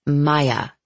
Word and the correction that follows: Mia: Mia, Francie and I both corrected at the same time.